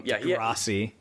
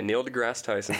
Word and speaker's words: Neil deGrasse Tyson: Degrassi. – Yeah. Isn't that how you say it? Degrassi? – That's a show Tyson? From the 90s about a bunch of Orange County kids. – Okay, Neil deGrasse Tyson.